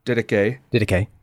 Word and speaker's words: Didache: But I'm probably going to butcher the name, so correct me, but the question is, what is the Didache? – Didache, yeah. – Got it on the second try.